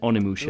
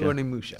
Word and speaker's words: Onimusha: Onimusha? Is that how you pronounce it? – Onimusha. – Onimusha.